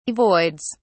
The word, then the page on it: avoids: British English